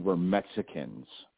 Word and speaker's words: Mexica: Okay, we were always under the assumption that we were Mexicans, and let's get this clear. That's how the Spanish pronounce Mexica. Okay, they couldn't understand that X has a SH sound in the language of Nahuatl.